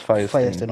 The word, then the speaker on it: Feuerstein: Yeah, it's pronounced Feuerstein.